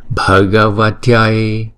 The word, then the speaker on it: Bhagavatyai: Bây giờ chúng ta cái cách chúng ta đọc là như nè, Om Om Namo Namo Bhagavatyai Bhagavatyai, cũng như là TA nhưng mà không đọc là TA, TAI TAI Bhagavatyai Arya Shri,